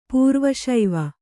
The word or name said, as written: ಪೂರ್ವಶೈವ